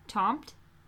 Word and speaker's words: Tomte: I would say that the taunt. – Mm. – How do you say it? – Taunt. T-O-M-T-E.